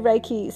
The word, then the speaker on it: Reiki: It's also the proper Japanese pronunciation is Rei, R-Y-E, Ki, K-E-Y, and it has been westernized to Rei, R-A-Y-K-E-Y. So the Rei, R-E-I, which is the first three letters of Reiki, means universal, omnipresent.